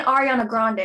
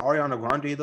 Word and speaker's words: Ariana Grande: Yeah, it's not Ariana Grande. It's Ariana Grande.